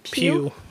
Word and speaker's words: Pugh: Pugh.